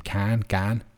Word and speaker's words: Gane: How do you pronounce his last name? Gane. So we'll see.